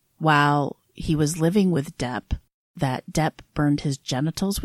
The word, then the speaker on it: Doep: When she found them, she took them to her employer, who we only know by the name of Depp, but it's spelled D-O-E-P.